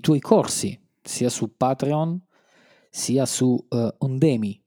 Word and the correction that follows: Udemy: giusto? Si pronuncia così? – Eh, credo Udemy.